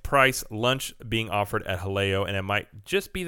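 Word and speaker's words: Jaleo: This is Almuerzo Rapido Fixed Price Lunch Debuts at Disney Springs Haleo. Which I believe I pronounced that right. We had a lesson in that one time.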